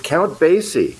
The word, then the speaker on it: Count Basie: Count Basie. – Yeah, Count Basie. – From the mid 70s. – You know, you're thinking of Shirley Bassey. There's Shirley Bassey and there's Count Basie.